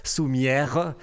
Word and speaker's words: Soumiaires: Alors, Les Soumières, L-E-S, plus loin, Soumières, S-O-U-M-I-A-I-R-E-S, Soumières, mais qu'on prononce Soumières en occitan.